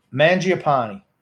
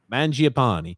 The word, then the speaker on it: Mangiapani: I think again the third goal is one that Varlamov, even though it was redirected by Mag- uh, Magapani. What how do you pronounce his name? Mangiapani. Mangiapani. Mangiapani.